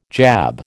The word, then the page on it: jab: American English